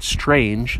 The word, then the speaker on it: Strang: I'm not trying to say the word strange. That's different. This is Strang with a G, hard G.